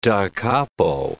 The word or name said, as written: da capo